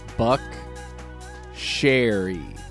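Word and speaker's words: Buckcherry: This week we are discussing Buck Cherry. Did I say it right? – Yeah. – Okay, good. I was saying it wrong this week, so...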